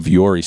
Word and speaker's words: Vuori: And I'm hoping I'm saying it right. It's V-U-O-R-I. It's been around. I have it. It's Vuori. They're Ponto pants and they're Strato Tech shirt.